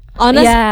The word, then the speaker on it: honest: Honest kan ada hanya kan di awal, walaupun bukan dua-duanya kata non vokal, tapi kayak it's still pronounced as honest, bukan honest.